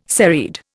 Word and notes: serried: Indian

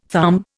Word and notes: thumb: Indian

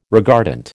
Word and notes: regardant: American English

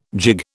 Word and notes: gigue: British English

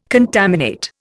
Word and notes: contaminate: Indian

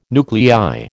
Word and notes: nuclei: American English

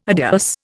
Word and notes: adios: Indian

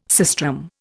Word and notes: sistrum: Indian

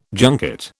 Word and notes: junket: British English

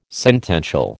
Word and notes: sentential: American English

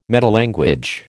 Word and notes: metalanguage: American English

